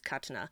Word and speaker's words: Katana: They say Katana, but it's the correct pronunciation of the word is Katana.